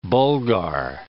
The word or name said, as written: Bulgar